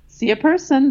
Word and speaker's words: Cea Person: Cea Person.